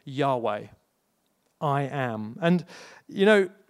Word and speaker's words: Yahweh: So it was simply the letters YHWH, which when pronounced, and we're not sure exactly how it was pronounced, but most likely is pronounced Yahweh.